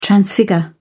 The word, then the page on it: transfigure: British English